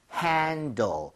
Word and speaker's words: handle: H-A-N-D-L-E. Handle. 이게 바로 handle. 손잡이가 되겠습니다. – 네. – 발음할 때도요. L 발음 좀 신경 써서 L 발음은 혀끝이 윗니 입천장 사이 앞쪽으로 가야 됩니다.